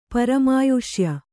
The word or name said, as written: ಪರಮಾಯುಷ್ಯ